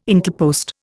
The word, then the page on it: Intelpost: Indian